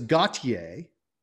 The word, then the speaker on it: Gotye: And so I thought it could very well be Gotye. – Okay. – It is not, it is Gotye, Okay.